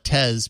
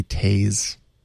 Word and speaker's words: Tez: It's fun to call it Tez because it rhymes with Pez. – Tez, yes. – But it's actually Taze. – Taze? Like apparently. Yeah, but not spelled that way. It's more spelled T-E-Z, but it's pronounced Taze.